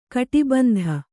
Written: ಕಟಿಬಂಧ

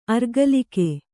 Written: ಅರಗಳಕ

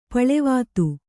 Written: ಪೇಲವತೆ

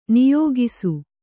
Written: ನಿಯೋಗಿಸು

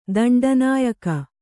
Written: ದಂಡನಾಯಕ

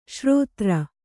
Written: ಷೇರುದಾರ

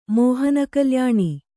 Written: ಮೋಹನಕಲ್ಯಾಣಿ

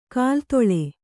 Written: ಕಾಲ್ದೂಳಿ

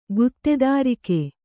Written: ಗುತ್ತೆದಾರಿಕೆ

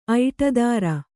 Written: ಐಟದಾರ

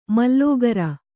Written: ಮಾಲಗಾರ

